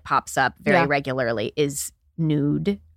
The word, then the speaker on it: Nuud: N-U-U-D. – Is that how we're saying it? – I believe so. It's Dutch. I don't understand Dutch pronunciation rules, but I think in the United States we pronounce it nude.